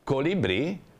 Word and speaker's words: colibrì: Colibrì, colibrì, una parola trisillaba, in questo caso tronca perché ha l'accento tonico sull'ultima.